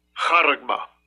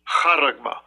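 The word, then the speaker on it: Charagma (χάραγμα): So the word mark is a G5480, and here's the pronunciation. – Strong's G5480, Charagma. Charagma. – That's how you say it. That's how you say the word mark.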